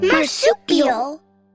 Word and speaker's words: marsupial: There are more soupy eels? – You almost said it right. Let's practice one more time and say marsupial on the count of three. One, two, three. – Marsupial.